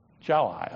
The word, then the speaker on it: Chile: The other day, I was in Chile, I guess is how you say that. Is that right? Chile, okay. That was with Herm yesterday,